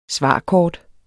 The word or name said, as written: svarkort